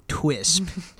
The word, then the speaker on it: Twisp: Oh, Twisp. so Twisp, that is not a mispronunciation. – That's T W I S P, not T.